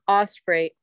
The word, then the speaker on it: Osprey: It's Osprey, O-S-P-R-E-Y. My middle name that I use as my name also fully is Oriel, O-R-I-E-L-L-E, and then my last name, Lake.